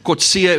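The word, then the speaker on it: Kotze: My surname is Kotzia.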